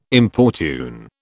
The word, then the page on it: importune: British English